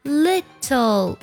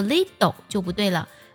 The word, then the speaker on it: little: 美式的发音当中呢，我们会将 little 当中的 t 浊化成的的音，读作 little。对，就是把这个 t 的音呢读得像的的音就好了，但不能把这个的的音读太重，读作 little 就不对了，而是 little，很轻很快的一带而过。